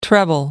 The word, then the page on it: treble: American English